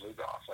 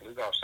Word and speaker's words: Lugoff: But once I started working with Hacksaw, he was living up in I think it's pronounced Lugoff, like Lugoff, South Carolina.